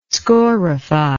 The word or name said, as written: scorify